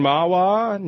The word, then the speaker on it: Mahwah: Let's go to Judy in Mahwah, New Jersey. Did I pronounce that right, Judy? – Yep, that's right.